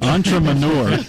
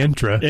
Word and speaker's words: entrepreneur: Entrepreneur. See, he started to say intra. – Intra, then entrepreneur.